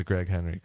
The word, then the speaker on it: Greg Henry: More people give jobs to Greg Henry because, geez, I hope I'm getting his name right.